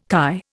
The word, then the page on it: kai: Indian